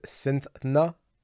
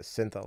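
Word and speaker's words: Cythna: Also, the correct pronunciation for the name of the fictional character who exemplified woman as human being is Cynthna. I think I pronounced it Cyntha last time.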